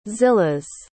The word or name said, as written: zillahs